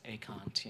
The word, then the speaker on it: Ekant: I'm like, it's Ekant, you know, pronounced it.